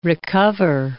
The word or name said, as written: recover